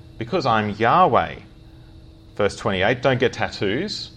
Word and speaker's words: Yahweh: They thought it was probably pronounced Jehovah. Now we think it's probably pronounced Yahweh.